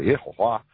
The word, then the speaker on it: Yehovah: it says in Chinese Yehovah. Or the way they pronounce it, Yehovah.